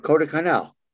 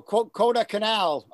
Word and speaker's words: Kodaikanal: And he was up at that time at Kodaikanal.